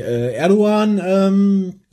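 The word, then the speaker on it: Erdoğan: Ist aufgefallen durch die Erdogan, das Erdogan-Schmähgedicht. – Sehr gut ausgesprochen, weil das G spricht man im Türkischen nicht aus, wenn es so ein Strich hat.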